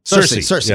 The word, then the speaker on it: Cersei: Sesame Street just released a Game of Thrones video with, is it pronounced Cersei? – Cersei.